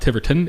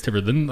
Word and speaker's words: Tiverton: you got Tiverton, which is like this cool little spot that for some reason is not Tiverton, it's Tiverton.